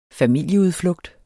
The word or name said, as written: familieudflugt